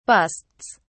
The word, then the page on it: busts: British English